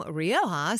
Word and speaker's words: Rioja: For those of you listening at home, Rioja, spelled R-I-O-J-A. I learned that several years ago when I tried to spell Rioja without looking it up. No, it wasn't the way I spell it. R-I-O-J-A.